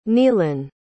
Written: neilan

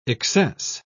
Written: excess

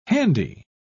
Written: handy

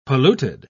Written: polluted

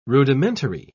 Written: rudimentary

